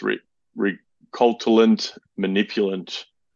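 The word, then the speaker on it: récoltant manipulant: It's I could never pronounce it, but it's recultant manipulant. RECOLTANT, new word, MANIPULANT, signifying that these are fruits grown, harvested, and produced by the same owners of the vineyards.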